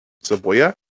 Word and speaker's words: cebolla: Cebolla? Am I saying it right? Cebolla. That's how the little Spanish pronunciation thing said it.